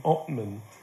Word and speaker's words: Altman: and Americans pronounce my name Ottman.